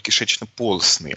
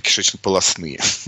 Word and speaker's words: кишечно-полостные: Следующие по сложности это представители типа стрекающие, те, кого в школьной программе называют кишечно-полостные, ну или кишечно-полостные, можно и так ставить ударение, полипы, медузы.